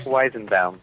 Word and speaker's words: Weizenbaum: Weizenbaum.